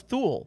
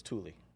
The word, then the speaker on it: Thule: I thought it was Thule. – Yeah, that's what everyone thinks it's Thule, but it's Thule.